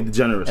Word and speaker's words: DeGeneres: How you say her name? DeGeneres? Ellen DeGeneres show. – Yeah, DeGeneres.